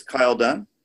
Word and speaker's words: Kyle Dunn: And our guest today is Kyle Dunn of Mailer Capital. Kyle, did I pronounce that correctly? – Brilliantly. Well done.